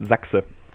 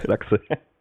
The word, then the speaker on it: Sachse: No, I pronounce it Zaxit. – Zaxit. – Yeah, you can pronounce it English. It's sexy then, but better go with Zaxit.